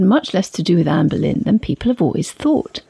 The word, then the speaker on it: Boleyn: Anne Boleyn must rate as one of the most misunderstood figures in British history, and also one of the most mispronounced. She probably would have called herself Anne Bullen.